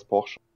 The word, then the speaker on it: Portia: My Time at Porsche, med P-O-R-T-I-A. – Yes. Altså. – Skrives Portia, men det uttales Porsche.